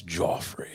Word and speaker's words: Joffrey: I don't know if it's pronounced Joffrey or Jeffrey, but it sounds like, just from the looking, it's Joffrey. – You know he gets pissed off when people call him Jeffrey. It's Joffrey.